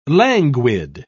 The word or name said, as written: languid